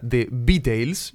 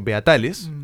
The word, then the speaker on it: The Beatles: que se pronuncia The Beatles. Yo la estaba pronunciando Beatales, como buen yoruba.